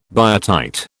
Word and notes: biotite: British English

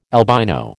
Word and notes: albino: American English

